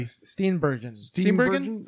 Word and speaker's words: Steenburgen: His wife, Mary Sturgenberg. Yeah. I always mispronounce her name. Yeah, I'm very so sorry. You know, Back to Future 3. – Steenburgen? – Again, yeah.